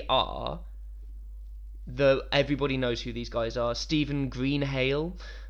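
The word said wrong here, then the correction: Greenhalgh: Greenhalsh is how that's pronounced.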